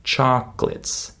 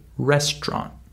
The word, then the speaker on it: chocolates: That's how my students say this word, but that's not how native speakers say this word. We skip the middle syllable. Chocolates. Just like with the word restaurant. We don't say restaurant, we say restaurant. We skip the middle syllable uh, due to the stress in this word.